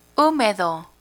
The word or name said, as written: humedo